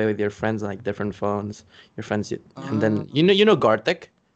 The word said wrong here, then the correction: Gartic: Gartic, oh damn.